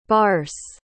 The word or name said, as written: barse